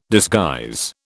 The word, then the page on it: disguise: British English